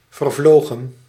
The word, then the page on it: passé: France (Paris); /pa.se/